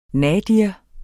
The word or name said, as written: nadir